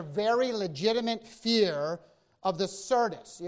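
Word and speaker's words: Syrtis: I'm going to not say this right. Anybody want to help me out? Sirtis. Thank you. Sirtis.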